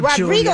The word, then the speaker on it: Rodrigo: Hey, gang, we have some Olivia Rodrigo. – Rodrigo, that's why I thought I said it right, but then I questioned my pronunciation.